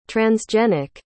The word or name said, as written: transgenic